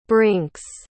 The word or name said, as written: brinks